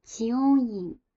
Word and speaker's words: 知恩院: 在京都有一个非常有名的寺庙叫齐翁院，那它的汉字的中文发音就是知恩院。